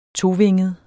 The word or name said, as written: tovinget